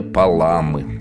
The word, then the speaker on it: Паламы: Вторая неделя святителя Григория Паламы. – Как? Паламы? Фамилия необычная. – И с ударением здесь непросто, потому что по-церковно-славянски правильно Паламы, а по-русски Паламы.